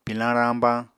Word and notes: pilañ rañbañ: Apatani

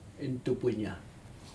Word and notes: intup inya: Aneityum

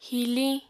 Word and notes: hiliñ: Apatani

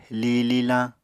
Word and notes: li lilañ: Apatani

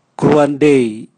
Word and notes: gruadei: Raga

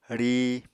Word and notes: ríi: Apatani

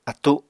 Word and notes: attu: Apatani

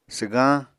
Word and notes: swgañ: apatani

